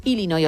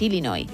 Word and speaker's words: Illinois: Pero es Illinois, o sea, si tú lo pronuncias bien y tienes buena dicción, es Illinois.